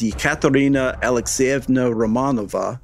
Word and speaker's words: Yekaterina Alexeyevna Romanova: The Russians knew her as Yekaterina Alexeyevna Romanova, or you know, history knows her as Catherine the Great.